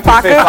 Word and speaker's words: Facu: How do you say Compazzo's first name? – I don't know. Would you like Facu? Yeah, Facu Compazzo.